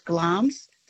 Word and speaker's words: Glamis: It's spelt G-L-A-M-I-S, Glam's, but it's pronounced Glam's thistle.